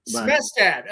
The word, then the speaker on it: Smestad: Smestad, okay.